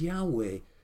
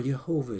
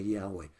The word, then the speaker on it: Yahweh: Yahweh, or Jehovah, as some pronounce it, although it's more accurate to say Yahweh.